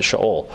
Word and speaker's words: Sheol: "For you will not abandon my soul to Sheol," or Sheol. We call it Sheol, but in Hebrew we pronounce it Sheol.